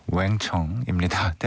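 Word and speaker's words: Wang Chung: 완청의 노래입니다. – 왕청이죠. 왕청. – 왕청. – 네. – 왕청이라고 해야 돼요. 왕청이 아니고 그쪽이 왕 발음을 못하잖아요. – 아, 네. 네. – 왕청입니다.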